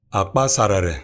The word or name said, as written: Akpasarere